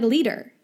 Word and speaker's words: litre: L-I-T-R-E, yeah. – It makes no sense because you pronounce it like liter, but is E-R, not reh or re.